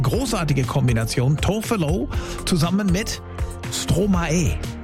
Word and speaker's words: Stromae: Na, ich weiß jetzt, wie man den ausspricht. Hab den mir letzte Woche geschrieben, hab gesagt, Mensch, der hat doch einfach Maestro umgedreht, die Silben, und deswegen heißt der Stromae.